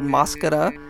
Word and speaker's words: Mascara: The last one was from a band called Mascara. It's like mascara with an accent on the first A there.